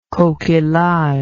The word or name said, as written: coquillage